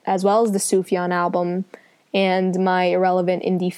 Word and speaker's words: Sufjan: Yeah, Sufjan. It's like Suf and then Yon.